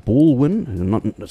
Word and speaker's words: Balwin: Bourwon. Some people mispronounce it or misspell it as Baldwin, but this is there's no D in it.